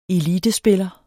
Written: elitespiller

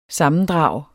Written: SAMMENDRAGENE

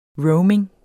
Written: roaming

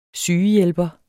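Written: SYGEHJÆLPER

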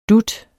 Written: dut

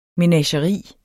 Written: menageri

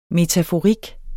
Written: metaforik

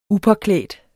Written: upåklædt

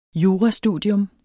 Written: jurastudium

